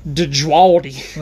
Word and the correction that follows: Djawadi: Oh, Jawadi. – Okay. – Yeah, these are... – The D is silent. – It's kind of like Django. – Ah, yes. Yeah. Ramin Djawadi. – Djawadi.